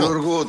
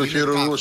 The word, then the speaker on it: χειρουργού: Τύπου, του χειρούργου, τύπου κρόκς, όχι κρόκς, σκλαβερόκς. – Ό,τι τονίζεται στην παραλίγουσα είναι το αρνητικό. – Του χειρουργού, του χειρουργού, συγγνώμη.